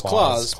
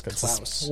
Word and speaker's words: Klaus: I thought it was Klaus, but it's Klaus.